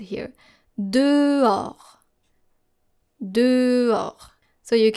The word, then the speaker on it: dehors: You probably have learned this word if you still practice reading French and practice writing as well, but you are very tempted to pronounce the H in this word, especially native English speakers. And this is how we spell this word. D-E-H-O-R-S. Encore une fois, D-E-H-O-R-S.